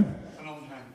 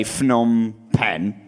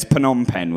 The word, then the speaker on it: Phnom Penh: There's three stages of thinking you know how to pronounce Phnom Penh. The first is that you say Phnom Penh, which is wrong.